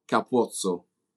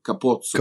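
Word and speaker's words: Capuozzo: Yeah, it's Ange Capuzzo. – Oh, gosh. Capuzzo, Capuzzo. – Capuzzo. – Capuzzo with O, not with A. O. Capuzzo. – Capuzzo.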